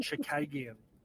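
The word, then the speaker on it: Chookagian: Also, I'm pretty sure old mate's name wasn't Chukagan. I think she's pronounced Chukagian. And I think she lost though. But yeah, pretty sure it's Caitlin Chukagian.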